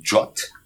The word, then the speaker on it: JWT: le prononce un, Jot ça se prononce Jot ok ouais ouais Jot voilà